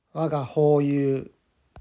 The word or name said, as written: Waga Houyu